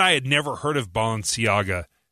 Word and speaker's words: Balenciaga: Balenciaga, as it's pronounced, makes like $2,000 purses and $1,100 tennis runners, tennis shoes.